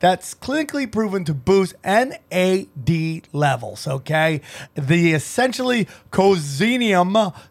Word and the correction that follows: coenzyme: C-O-E-N-Z-Y-M-E. How do you say that? Cozinium. – Coenzyme. – Coenzyme. I like that.